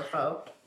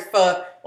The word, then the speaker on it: pho: But that's like, Therese, you be eating pho a lot. Or pho.